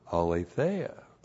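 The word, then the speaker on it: aletheia (ἀλήθεια): Alethenos is an adjective that is related to the noun, you know the noun, aletheia. A-L, long E-T-H-E-I-A.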